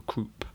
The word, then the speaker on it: croup: He contracted the old crew, or croup, if you're pronouncing the P.